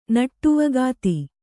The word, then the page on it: ನಟ್ಟುವಗಾತಿ: Kannada